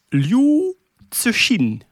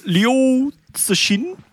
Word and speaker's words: Liu Cixin: Ehm, de uitspraak is Liu Tsushin. Tsushin. – Dat is toch wat dat we zeiden? – Liu, Liu Tsushin.